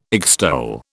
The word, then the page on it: extol: British English